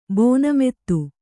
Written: ಭಾನುಮತಿ

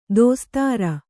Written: ದೋಸ್ತಾರ

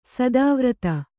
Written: ಸ್ಥಾವರತೆ

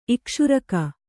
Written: ಇಕ್ಷುರಕ

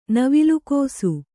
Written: ನವಿಲುಕೋಸು

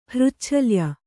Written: ಹೃಚ್ಛಲ್ಯ

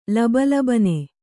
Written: ಲಬಲಬನೆ

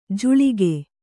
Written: ಝಳಕ

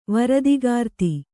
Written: ವೃಥಾಕೃತ

